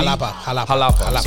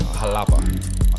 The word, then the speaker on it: Jalapa: This is 5-year age from Esteli and Jalapa. It's a woody. – Jalapa. – Sorry, Jalapa. My Spanish sucks. – J's are pronounced like H's.